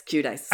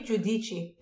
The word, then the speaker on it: Giudice: Judice.